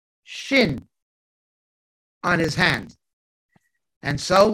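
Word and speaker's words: shin (שין): shin, shin, which is the word for tooth, and it's pronounced S-H, like shout, shout, shin, tooth, shenayim.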